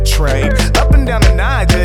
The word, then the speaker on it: Niger: Come on, let me take you to a river, Niger. Soft G like a J in pajama.